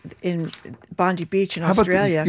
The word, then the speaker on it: Bondi: It's Bondi Beach.